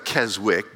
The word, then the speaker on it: Keswick: There was a Keswick, it looks like Keswick, but it's pronounced Keswick.